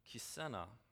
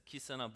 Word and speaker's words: Kissena: K I S S E N A, uh, I pronounce uh, Kisena. Kisena. That's, uh, that's what I said. It's Kisena Boulevard.